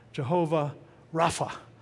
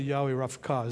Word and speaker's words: Yahweh Rapha: Yahweh Rapha, Jehovah Rapha, depending upon your pronunciation. Yeah, right. Actually, Yahweh Rapha is the right one, but we won't go there anyway.